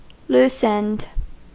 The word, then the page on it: loosened: American English